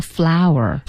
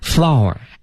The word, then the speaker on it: flour: 面粉这词儿我最喜欢，因为它那读音吧，跟那花儿一样，叫 flower。 – flower。 – f l o u r。